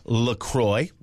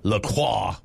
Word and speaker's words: LaCroix: Officially, it's pronounced LaCroix, so we got it right. And, you know, it's not like LaCroix, which would be, you know, way sure. too French.